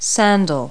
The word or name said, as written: sandal